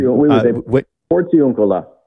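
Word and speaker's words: Porziuncola: But let's start with tomorrow, because tomorrow I'm not going to say this properly, but at the Porzioncola, lying on the bare ground. – Close. – Was that close? Okay. Yeah. Help me out here. – Porzioncola. We would say Porzioncola.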